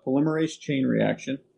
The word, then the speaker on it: polymerase chain reaction: So, yeah, polymerase chain reaction.